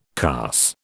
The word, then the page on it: carse: British English